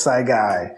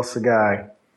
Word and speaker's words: Saigai: I know it's it's French, as this man just uh, saluted, but it's Saigai, so Noel Saigai.